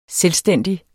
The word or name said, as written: selvstændig